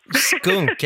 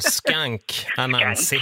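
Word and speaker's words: Skunk Anansi: Skunk, eller man uttalar väl skank Anansi. Skank. Heter bandet Skank Anansi.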